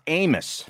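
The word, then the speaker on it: Abmas: It's spelled Abmus, but it's pronounced Amos for some reason.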